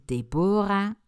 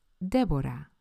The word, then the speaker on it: Deborah: Want hoe spreek je die nou uit? Is het Deborah, Debra, Deborah? Ik hoor van alles voorbij komen. Nou, het zijn ze alle drie niet. Mijn naam spreek je uit als Deborah. Zo spreken die nou eenmaal in het Spaans uit.